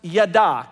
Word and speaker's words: yada: The biblical definition to know is pronounced yada. Can you say yada? Yada.